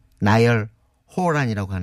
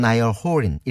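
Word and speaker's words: Niall Horan: 이런 뜻인데 어, 부른 가수는 우리는 흔히 이제 어, 나열 호란이라고 하는데 네. – 어 외국에선 나이얼 호린 이렇게 발음을 음.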